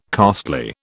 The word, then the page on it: costly: British English